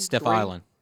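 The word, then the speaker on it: Stephiylan: Is it Stephlian? – Steph Island. Yeah, Steph Island.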